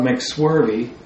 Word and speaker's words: McSwervy: Okay, SoundCloud McSwervy.